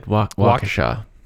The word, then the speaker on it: Waukesha: On May 31st, 2014, two 12-year-old girls in Waukesha, Wisconsin. Did I say that right? – You did. Waukesha.